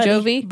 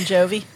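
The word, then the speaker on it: Jovie: And the movie wraps up with Buddy and Jovi. Did I say that right? Buddy and Jovi. – Buddy and Jovi. Yes. You got it.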